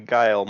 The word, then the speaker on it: Guile: In that scene where they're in the hospital, I noticed that, so Guiles is how you pronounce his name? I'm so sorry. – Guile, yeah.